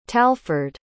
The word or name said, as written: talford